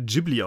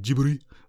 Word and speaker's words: Ghibli: Ähm, das Studio wird übrigens tatsächlich Studio Ghibli ausgesprochen, bevor wir jetzt erboste Zuschriften bekommen. Könnt ihr mal nachlesen, warum heißt auch im Japanischen irgendwie Ghibli. Die meisten würden ja sagen, es müsste Ghibli ausgesprochen werden, weil das ein italienisches Wort ist. Das ist auch korrekt. Aber die Japaner bestehen darauf, dass ihr Studio halt so ausgesprochen wird.